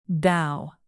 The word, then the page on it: bow: motion